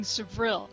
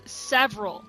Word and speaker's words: Sevril: Yeah, I know Rick has a hard time pronouncing Sevril. He says several.